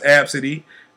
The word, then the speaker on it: Abcde: But the girl's mother claims that the agent mocks the girl's name, App City, Redford, and the App City is spelled A B C D E. And laughed at her while they were boarding the plane. The girl's name is pronounced Absidy.